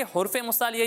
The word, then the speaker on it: حروف مستعلیہ: حروف مستعلیہ۔ حروف مستعلیہ، ٹھیک ہے۔ حروف مستعلیہ یہ کچھ نہیں ہوتا، حرف یہ غلط تلفظ ہے، حروف مستعلیہ میں سے، ٹھیک ہے؟